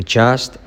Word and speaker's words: just: I'm just joking. Muy bueno para la letra clave J, que hay dos. I'm just joking. La T de just casi no se dice.